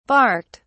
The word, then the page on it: barked: British English